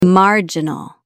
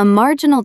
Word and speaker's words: marginal: A marginal difference is so tiny, it barely moves the needle. Stress the first syllable. Marginal. Try saying it.